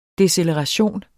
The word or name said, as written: deceleration